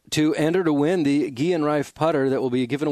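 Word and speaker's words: Guerin Rife: I've looked it up, and it's G-U-E-R-I-N. – Rife is another word, R-I-F-E, putter, but it's pronounced Guillen Rife.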